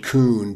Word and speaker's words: Kuhn: Kurt Flood was a very good Major League Baseball player, and Bowie Kuhn, and that's Bowie, like the Bowie knife, and Kuhn, but it's spelled K-U-H-N.